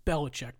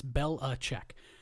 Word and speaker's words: Belichick: That's how you say his name. It's Belichick. Don't worry about how it's spelled. It's Belichick.